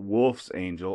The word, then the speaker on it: Wolfsangel: And that's spelled W-O-L-F-S-A-N-G-E-L, Wolf's Angel, all one word. But back in the late 30s Germany, they pronounced it a different way. And it's Wolf's Angel.